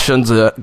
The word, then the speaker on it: Sun Tzu: But to make the bridge here, as Shunzu said, or Sun Tzu, as people say, it's pronounced Shunzu, guys, in The Art of War, he says, in the midst of great chaos, there is also great opportunity.